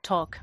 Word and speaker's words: talk: Talk. Mm-hmm. – Y ahí es el mismo caso que walk. – Que walk. – No se pronuncia esa L.